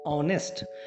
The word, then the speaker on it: honest: Okay, what I am speaking, honest, how I am pronouncing, honest. But you need to remember here, the spelling of word honest is what? H-O-N-E-S-T. So here word H is a consonant but producing vowel sounds.